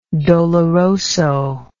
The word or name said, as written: doloroso